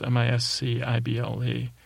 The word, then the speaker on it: miscible: It's not mixable, like mispronounced. It's miscible. Looks like imbecile, which is me.